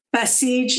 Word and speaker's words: Basij: It seems like there's the police and then there's the, I don't know how to pronounce this, B-A-S-I-J, Basige? – Basige, yeah.